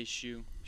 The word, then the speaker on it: Hsu: Up next we have Stephanie Hsu. If I'm saying that right, I don't know. As I would say Hsu.